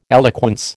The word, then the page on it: eloquence: American English